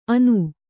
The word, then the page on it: ಆನು: ānu